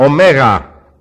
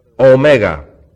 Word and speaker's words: omega: Now this is a long O, and it's drawn out a bit. Remember the 15 was a short O. This is O. Omega. Okay? The other is O. Micron. It's a little one. Just kind of pops out. Omega. Omicron. Excuse me. Omega. Omicron. O and O.